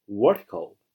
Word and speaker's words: vertical: 第一个单词形容词，垂直的、直立的啊，垂直的、直立的，读作 vertical。 vertical，重音在最前面。